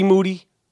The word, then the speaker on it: Moody: Moody. Last name rhymes with duty.